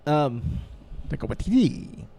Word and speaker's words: Taika Waititi: I'm very excited about Ragnarok, though, because of Taika Waititi. I hope I'm pronouncing that correct. – You just like to say that. – It's a fun name. – Taika Waititi.